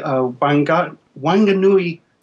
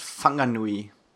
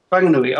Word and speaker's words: Whanganui: And an unfortunate incident, in 1847, an English sailor accidentally shot a Maori, which started the Whanganui campaign in the New Zealand Land Wars. – Ooh, ouch. It's actually pronounced Whanganui. – Whanganui, okay, yep.